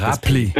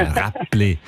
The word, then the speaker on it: Räppli: Du hast es mit einem B ausgesprochen. Ja. Und Räppli. – Ja, Räppli.